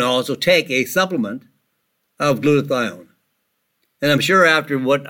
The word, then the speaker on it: glutathione: Now I remember when I opened up the program, I suggested we would talk about glutathione. It's spelled G-L-U-T, glut. Thione, T-H-I-O-N-E. G-L-U-T-A-T-H-I-O-N-E.